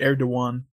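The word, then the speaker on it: Erdoğan: His last name is pronounced Erdogan.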